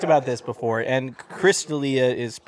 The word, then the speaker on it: D'Elia: It's Chris D'Elia.